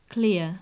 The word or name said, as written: clear